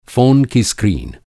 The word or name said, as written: स्क्रीन